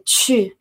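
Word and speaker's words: chu (去): Y bueno, esto ir directamente se dice chu. Muchas veces esta palabra chu le cuesta a todos pronunciarlo, el chu.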